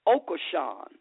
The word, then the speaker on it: Okosun: His name is Ambrose, and I think it's pronounced Okoshun, O-K-O-S-U-N,